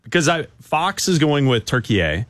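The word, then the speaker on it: Türkiye: But also when you read on the graphics, different channels are going with turkey A.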